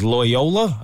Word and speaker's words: Loyola: I think her university is Loyola. I believe that's how you pronounce it. – Yeah.